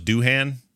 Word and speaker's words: Doohan: James Doohan, and I always thought it was Doohan, but I'm told by Daryl, the Trek nerd Skeels. – And Daryl knows. – And he does know that it's pronounced Doohan, and it always has been.